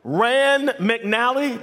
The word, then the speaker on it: Rand McNally: Now, I can actually remember Rand McNally, did I say that right? Yeah. Is that right? The Rand McNally maps.